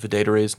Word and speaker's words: Adetare: So I was like, it rhymes with day to day, Adetare.